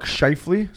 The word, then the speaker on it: Scheifele: And Mark Scheifele. How do you say his name? – Yeah, you got it.